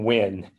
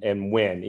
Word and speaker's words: Nguyen: My name is Thanh Nguyen, kind of like the old astronaut drink. – Nice. – Thanh, and Nguyen, even though it's spelled N-G-U-Y-E-N.